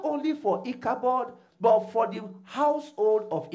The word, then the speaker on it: Ichabod: Sister Mary, how do you pronounce it? You see, I'm right. Ichabod.